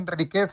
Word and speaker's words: Enríquez: Enríquez, no Enríquez. S'ha de pronunciar Enríquez sense títol.